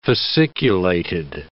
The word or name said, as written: fasciculated